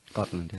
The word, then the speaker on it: Gotland: Gotland, yeah.